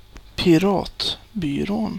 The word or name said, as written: Piratbyrån